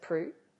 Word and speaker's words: Proulx: Is it pronounced Proulx? – Yeah, E Annie Proulx.